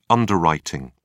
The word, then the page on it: underwriting: British English